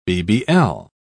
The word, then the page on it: bbl: British English